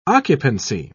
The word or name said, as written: occupancy